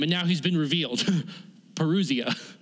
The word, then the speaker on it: parousia: One is erchomai, and one is parousia, or I butchered that word, parousia.